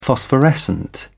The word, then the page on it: phosphorescent: British English